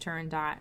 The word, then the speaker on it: Turandot: Turando once, and a woman corrected me, and she like took my hand and said, "It's actually pronounced Turandot," and I was like, "Get the fuck away from me!